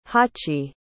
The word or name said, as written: hachi